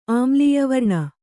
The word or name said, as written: ಆಮ್ಲೀಯವರ್ಣ